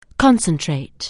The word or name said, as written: concentrate